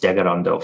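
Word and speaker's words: Tkaronto: Toronto is a Haudenosaunee word. So we pronounce it Degarondo. So a lot of people will see it spelled Tekaronto and they'll pronounce it as such, but it's actually pronounced Degarondo from my understanding.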